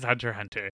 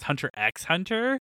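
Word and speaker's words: Hunter × Hunter: I always thought it was Hunter x Hunter. – Same. – So like, we're all in the same, like, this unnecessary silent X, because it is silent.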